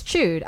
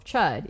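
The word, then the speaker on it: Chüd: It's chewed. I'm going to call it the Ritual of Chud. And you guys are going to sit there and you're going to take it. Because it's spelled C-H-U-D. Yes, I know there's an umlaut on the U.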